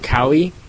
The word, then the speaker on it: Kauai: It's Kauai, yeah.